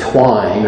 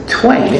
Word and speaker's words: twain: It's not twine. I've heard it pronounced that. It's twain. It means two. Take off the A-I-N, put on the zero, and an O, the letter O.